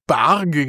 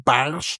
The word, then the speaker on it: Barge: Ich habe es beim letzten Mal noch Barge genannt, weil ich das so gelesen hatte. Und dann habe ich gelernt, es wird gar nicht Barge ausgesprochen, sondern Barsch.